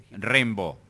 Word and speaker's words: Rimbaud: Rimbaud.